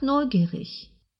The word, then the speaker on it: neugierig: Ich bin einfach neugierig. – Вы, несомненно, обратили внимание на то, что в слове «neugierig» последняя буква читается как «ich-laut».